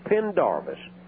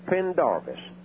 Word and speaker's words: Pendarvis: And they say, I can't pronounce your name. Well, it's Pendarvis, Pastor Albert Pendarvis. Just three little syllables, Pendarvis.